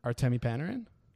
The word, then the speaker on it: Artemi Panarin: Artemi Panarin? – Artemi Panarin.